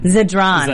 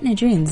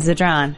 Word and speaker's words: Zadran: Zadran. Zadran. Zadran. Why did I put Nadrin? Zadran.